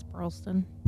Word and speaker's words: Burleson: Did I say that right? – I think it's Burleson.